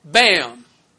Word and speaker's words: BAMN: The national structure and legal counsel for Antifa is run through a front group called BAM. It rhymes with DAM, B-A-M-N, which stands for By Any Means Necessary, and was originally called the Revolutionary Workers League.